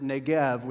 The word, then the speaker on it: Negev: It says Negev, all right? Or you could pronounce it Negev with a V sound.